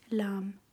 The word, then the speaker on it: Lam: Ja, wird auch normal L ausgesprochen wie bei uns und das heißt Lam.